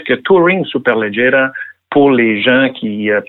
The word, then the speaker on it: Superleggera: Si on parlait maintenant de voitures exotiques, on va commencer par Touring Superleggera. Est-ce que j'ai la bonne prononciation? Bien prononcé.